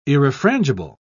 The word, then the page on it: irrefrangible: British English